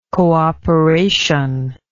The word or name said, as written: cooperationist